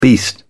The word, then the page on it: beast: British English